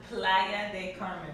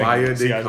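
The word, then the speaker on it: Playa del Carmen: Come on over here and say Playa de Carmen. – All right, thank you. Playa de Carmen.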